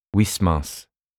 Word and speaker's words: Huysmans: Never know how to say that because it's a Dutch name, but he was French. Huysmans. And as far as I can see, the French pronunciation is Huysmans.